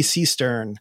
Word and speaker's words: Seestern: S T E R N. Seestern. Like a cistern, I suspect. Seestern. Like a. Si- could you guess? S-E-E.